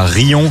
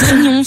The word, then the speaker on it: Riom: Il y a Rion, ça s'écrit R-I-O-M. – Et alors? – Et pourquoi vous faites ça? – Mais ça se prononce Rion, c'est on fait ce qu'on veut dans le 63.